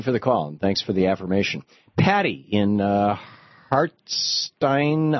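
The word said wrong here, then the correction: Harstine: Island, Washington. Am I saying it right? Listening to KBCS. – Harstein. – Good afternoon. Yes, Harstein Island.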